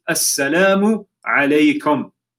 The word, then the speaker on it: Assalamu alaikum: People say it wrong. We need to make sure that we say salam properly. Assalamu alaikum. Right? Assalamu alaikum.